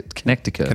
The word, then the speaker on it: Connecticut: It's all connected in Connecticut. Please, sell them. – Connecticut? – Yeah. Connecticut. – Connecticut. Yeah. That's how you remember how to say it.